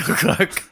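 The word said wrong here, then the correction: Koch: In my head, I was like, don't say Cock. But how do we say it? – Bill Cock. – Coke. Coke.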